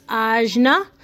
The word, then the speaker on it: Ajna: I like to associate it with royal blue, and it's pronounced Ajna, and it is located between both of your eyes.